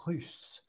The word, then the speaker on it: russe: Russe. – Another pronunciation exercise, the U, it sounds like ou. It's like U. U. You want to make your mouth very small and say like U. Russe.